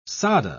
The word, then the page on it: sadhe: British English